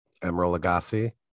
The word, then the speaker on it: Emeril Lagasse: Emeril Lagasse.